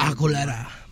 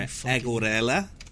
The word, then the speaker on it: Aguilera: Christina Aguilera. – No, I know. – Aguilera. You gotta say her name properly. – Oh, sorry. Aguilera? – Yeah, you racist pig.